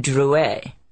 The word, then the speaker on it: Drouet: "It's pronounced Drouet. Our family was French on my father's side."